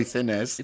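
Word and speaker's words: Thinnes: It's T-H-I-N-N-E-S. So is it Thinez? – Thinez. – Roy Thinez, you know? Fine actor Roy Thinez.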